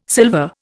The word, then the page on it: sylva: Indian